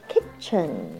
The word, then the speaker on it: kitchen: kitchen kitchen kitchen 高音在第一段T 在这边是 silent word 不发音 kitchen ch 发的是 ch ch 连在一起它就只有两个可能性一个是发 ch 的音一个是发 k 在这边是发 ch kitchen here